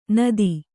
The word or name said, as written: ನಾದು